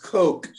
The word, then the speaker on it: coq: And sometimes C-O-Q-U-E, your spelling lesson today, Maison Fabre spells it C-O-Q. And then I said, I was wondering about the spelling. I thought maybe cock like rooster. And then I said, now I have a horrible image in my head of a cock hat. – Yeah. And it's pronounced coke.